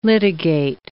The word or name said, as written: litigate